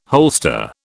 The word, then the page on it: holster: British English